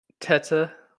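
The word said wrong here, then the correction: Tetê: or I think it's Tete. It's Brazilian pronunciations. Very strange. No, Tete. What a debut.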